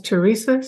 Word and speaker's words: Teresa: And my first name is Teresa, spelled without the H, and Peshi is five letters, PESCE.